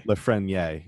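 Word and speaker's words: Lafrenière: I believe his name is Alex Lafreniere, if I'm pronouncing it right? – Lafreniere.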